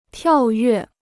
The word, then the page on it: 跳跃: tiào yuè